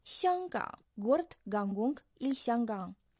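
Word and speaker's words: Сянгкан (香港): Сянгкан. Первый иероглиф «сянг» произносится в первом тоне, означает «аромат», «ароматный». Второй иероглиф «ган» произносится в третьем тоне, означает «залив», «бухта».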